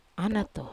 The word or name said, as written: anato